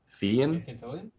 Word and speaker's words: Fillion: In the movie, Nathan Fillion. How do you say his name? Fillion.